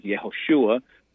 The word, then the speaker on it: Yehoshua: and we know the traditional pronunciation is Yehoshua, or Yeshua in its shortened form, and I understand your point of view on those things.